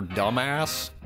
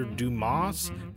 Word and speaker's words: Dumas: Isn't that the book that's written by a guy named Alexander Dumbass? – I mean, I think technically it's pronounced Alexander Dumas, but we try not to get too technical on this show.